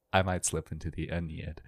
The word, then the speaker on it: Aeneid: Most people are confused when I pronounce it Aeneid. They're like, what is that? Because everyone apparently just says Aeneid. But at the end of the day, I'm just pronouncing it like a classics major does, which is that the A-E at the beginning makes a diphthong, which makes the I sound, so it's Aeneid.